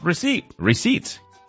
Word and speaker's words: receipt: Receipt. – Aunque lleva una P escrita, y al rato lo comparto con ustedes, esa P no se pronuncia,